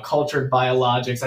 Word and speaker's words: Cultured Biologix: And today I'm actually in one of the local businesses here in Denver, Colorado, Cultured Biologix.